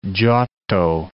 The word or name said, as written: Giotto